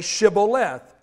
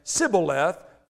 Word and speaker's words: Shibboleth: When they said, 'No,' they said to him, 'Then say Shibboleth.' And they said, 'Sibboleth,' for he could not pronounce it right.